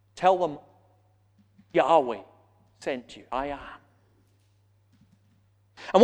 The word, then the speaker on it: Yahweh: Older translations spell out the four letters, put in the vowels, and they come up with Jehovah. Most modern scholars think it's pronounced rather Yahweh.